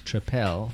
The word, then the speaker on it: Tripel: We are talking about the Belgian Tripel, which that is how it's pronounced correctly. Tripel. – Three. – And triangle. – Not triple. – Triple.